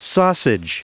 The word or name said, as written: sausage